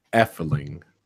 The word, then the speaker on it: Efteling: How do you pronounce that theme park in Efteling? – Efteling.